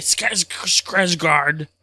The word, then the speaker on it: Skarsgård: He's one of the Skizgards, if I'm saying that right. Skarsgård. – I'm looking it up right now. It's Bill Skarsgård.